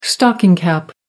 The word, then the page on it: stocking cap: American English